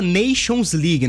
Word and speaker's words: Nations League: Exatamente, a famosa Nations League, né, a pronúncia tá em dia, Éboli.